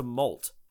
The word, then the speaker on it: molt: Melt? – Melt. – No, don't. – You're adding like 5 syllables to molt. – Yeah. – M O L T, as in they like shed the shell, right? – And it grows back harder.